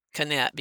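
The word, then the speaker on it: Knecht: And there's a picture of Beth Kinnett, and I pronounce it Kinnett because I went to school with a girl with that same spelling. It's K-N-E-C-H-T, so hope I was right.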